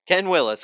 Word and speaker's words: Ken Willis: Ken Willis, spelled like it sounds.